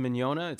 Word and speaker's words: Mignogna: My last name, when we were kids, we started in the entertainment industry as actors and musicians and things, and nobody could pronounce the Mignogna. It's spelled M-I-G-N-O-G-N-A. The G's are silent. – Spelled. – You know, like filet mignon.